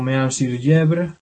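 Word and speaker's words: Mehun-sur-Yèvre: Ejemplo, este castillo, este château, de se pronunciaría algo como Meun-sur-Yèvre, eso aparece ilustrado en el libro, escrito Meun con H intercalada, Sur-Yèvre, con Y.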